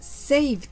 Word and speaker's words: saved: 発音で気をつけるのは saved です。 V の発音は下唇を軽く噛んで歯の間から息を強く吐いてください。